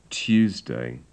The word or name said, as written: tuesday